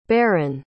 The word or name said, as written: barran